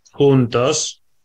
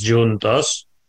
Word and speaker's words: juntos: They're totally different languages, but this particular word is spelt the same, although it's pronounced quite different. So in Spanish it's juntos and in Portuguese it's juntos, but it still means together.